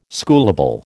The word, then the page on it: schoolable: American English